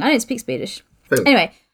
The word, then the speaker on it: Falun: Little entry is, mysterious accidents plague the Swedish mining town of Flaun. I don't know if I said that right. F-A-L-U-N. – Oh, okay. – Falun? Falun? Falun. – Falun. F-F-A... – L-U-N. – Falun. Yeah, yeah, sure.